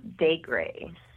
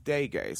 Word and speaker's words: Daigre: It's pronounced Daigre. – Daigre.